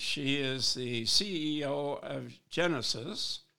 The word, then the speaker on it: Geenees: So Geenees, and is just imagining basically a genie in a bottle that makes wishes come true. And when you have lots of them, they become genies.